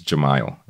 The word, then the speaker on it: Jamiel: As I've told you before, my name is Jamiel, right? And the way it's spelled is J-A-M-I-E-L, right?